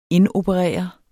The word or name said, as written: indoperere